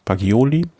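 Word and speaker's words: Bagioli: Andrea Baggioli, spricht man das so aus wahrscheinlich? – Baggioli von Deceuninck Quickstep im Jugendtrikot.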